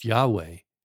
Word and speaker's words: Yahweh: Richard Rohr, the amazing Franciscan priest, says that even the written Hebrew name for God, Yahweh, YHWH, which when it is pronounced, gets pronounced Yahweh.